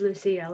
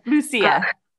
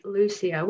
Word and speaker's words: Lucia: And the correct pronunciation is Lucia, like you said. – Lucia. – It's great to see that someone got that right. Most people I hear are like Lucia, which is understandable, but it's Lucia.